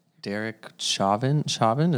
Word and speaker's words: Derek Chauvin: Yeah, and then we got the Derek Chauvin.